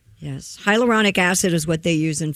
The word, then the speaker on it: hyaluronic acid: Is it pronounced hyaluronic acid? – Hyaluronic acid.